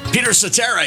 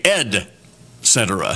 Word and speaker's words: Cetera: Peter Cetera's brother, Ed, pronounces his last name slightly different, although it's spelled the same way, Ed Cetera.